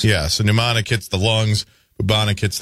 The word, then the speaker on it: pneumonic: Pneumonic. – Pneumonic. – As in pneumonia. – Yeah. Okay. All right. – That's just okay, silent P.